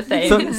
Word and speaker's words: Thames: Yes, I have to inform you, it's pronounced Thames. – It's the Thames. – Thames. – Thames is extremely good, though. Thank you. But what if, like... – I'm, like, trying... In German, we pronounce it Thames, but I was like, it's written so differently in English.